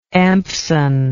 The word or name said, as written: Empson